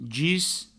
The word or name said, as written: gis